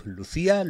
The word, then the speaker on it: LuzIA: ¿Cómo lo pronunciáis? Lucía, Lucía. Lucía. escribimos Luz IA, pero la llamamos Lucía.